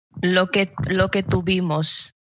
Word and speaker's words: Lo que tuvimos: Then we had Loke Tuvimos. Did I say that correctly? – Lo que tuvimos.